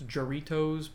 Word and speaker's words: Jarritos: It's Jarritos, but it's probably a J is an H because Juanitos.